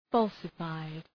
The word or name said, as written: falsified